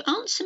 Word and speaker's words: answer: W-R-O-N-G. Can you answer me this? Can you answer me this? Is it wrong or is it right? Answer. Another silent W. A-N-S-W-E-R. Answer. And here's one. Sword. S-W-O-R-D.